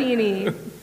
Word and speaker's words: pepperoncini: Peppercini potato chips. – Pepperoni-chini. – I know. That's why I didn't pronounce it. – Pepperoncini.